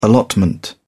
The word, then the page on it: allotment: British English